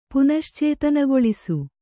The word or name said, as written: ಪುನಶ್ಚೇತನಗೊಳಿಸು